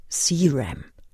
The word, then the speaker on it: Ceram: C.W. Ceram oder spricht man ihn Englisch aus? Ceram.